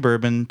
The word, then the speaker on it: bourbon: The name bourbon, pronounced Bourbon. – Bourbon. – Comes from the period when the island of Reunion was ruled by the Bourbon kings of France.